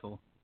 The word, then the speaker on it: Chytil: Chytil. – What is Chytil. It? – Chytil. – Chytil. – Like a kite. – Flying a kite.